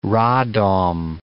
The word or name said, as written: Radom